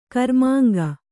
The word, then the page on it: ಕರ್ಮಾಂಗ: Kannada